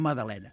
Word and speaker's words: Magdalena: Que es pronuncia Madalena. Clar, llavors després hi ha uns pastissos que se li diuen Madalenes, que en català s'ha d'escriure amb la G també, encara que pronunciem Madalena, perquè tant si és nom de persona com si és el pastisset, sempre la G tendeix a emmudir-se, però per escrit és Magdalena, perquè aquests pastissos se'ls va posar un nom de dona.